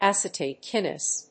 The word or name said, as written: acetate kinase